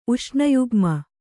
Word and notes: ಉಷ್ಣಯುಗ್ಮ: uṣṇayugma

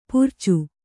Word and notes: ಪರಚು: paracu

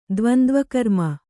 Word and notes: ದ್ವಂದ್ವಕರ್ಮ: kannada

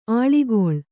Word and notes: ಆಳಿಗೊಳ್: āḷigoḷ

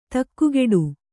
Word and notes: ದಿಗ್ಗೇಡಿ: diggēḍi

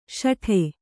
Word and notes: ಸಟ್ಟಾ: saṭṭā; Kannada

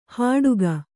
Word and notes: ಹಾಡುಗ: hāḍuga

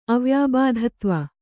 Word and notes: ಅವ್ಯಾಬಾಧತ್ವ: kannada

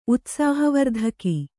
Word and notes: ಉತ್ಸಾಹವರ್ಧಕ: utsāhavardhaka

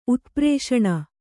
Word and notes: ಉತ್ಪ್ರೇಷಣ: utprēṣaṇa

